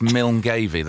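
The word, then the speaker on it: Milngavie: Listen, I've seen where Craig is enough to know that's Milngavy. That's how you pronounce it.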